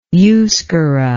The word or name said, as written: euskera